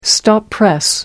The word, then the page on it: stop press: American English